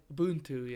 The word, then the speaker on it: Ubuntu: Ubuntu.